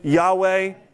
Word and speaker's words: Yahweh: Now, if there's any biblical theologians in the house, YHWH stands for, how do we pronounce it usually? Yahweh.